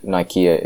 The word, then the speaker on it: Nicaea: Well, that's simply not true, because in Constantine's time was held the Council of Nicaea, or Nicaea, however you pronounce it. I think Nikea is the correct pronunciation. Nike or Nike in Greek is victory, and Nikea was the site of victory. So I think the second pronunciation is probably correct.